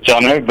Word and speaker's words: Joneaux: I didn't do myself any favors with my name, but it's spelled J-O-N-E-A-U-X, and you just pronounce it Jonner, but if you search Songs South Africans Forgot About, I should come up.